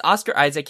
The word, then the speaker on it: Oscar Isaac: Yeah, it's Oscar Isaac.